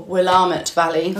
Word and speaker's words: Willamette: So this is from Willamette Valley as well, or Willamette Valley. – Oh, is that how they say it? – Yeah. – I always say Willamette.